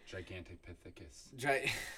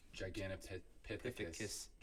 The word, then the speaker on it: Gigantopithecus: Gigantopithecus. – Thank you. Yeah. They think it's either evolved from that point of, like, they know those existed. The gigantic. – Gigantopithecus.